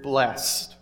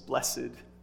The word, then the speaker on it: blessed: And you'll notice that each statement begins with the same word, and that's the word blessed, or if you prefer the old-fashioned way to say it with two syllables, blessed.